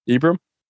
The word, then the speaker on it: Ibrahim: Ibrahim Sadiq. I think I did pretty good. – Ibrahim? – I-B-R-A-H-I-M. Ibrahim? – Ibrahim. like Abraham with different vowel sounds. – Yeah, the A's are exchanged for I's.